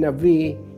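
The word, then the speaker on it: Navi (נביא): When we, in modern Hebrew, maybe, pronounce the name Navi, you don't feel, you don't hear the Aleph, but it was felt, it was pronounced.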